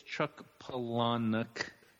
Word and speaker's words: Chuck Palahniuk: And for all those people that don't know how to say it, it's Chuck Palahniuk.